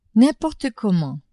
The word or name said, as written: n'importe comment